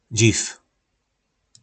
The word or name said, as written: Dziv